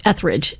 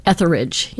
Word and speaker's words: Etheridge: You know, because I think most people say it as two syllables, Etheridge, instead of Etheridge. You know what I mean? So it is confusing. If you sound it out the way it's written, Etheridge, right? – Oh. – Isn't there an E-R in the middle? – Yeah. – T-H-E-R-I. Yeah, so it would be three syllables, Etheridge.